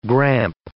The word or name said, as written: gramp